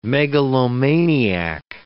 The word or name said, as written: megalomaniac